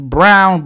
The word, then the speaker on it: Braun: Now, it's spelled like Braun. I've never seen anyone whose last name is pronounced Brown, but it's spelled B-R-A-U-N. So I've had a few times where I've mispronounced it because I'm sounding it out like it's spelled.